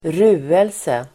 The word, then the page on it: ruelse: [²r'u:else]